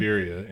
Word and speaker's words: Liberia: It's Liberia, and it's in Africa, if I'm not mistaken.